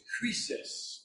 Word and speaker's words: krisis: The Greek word for judgment is krisis, or in the English, if you were to see it, you would pronounce it crisis.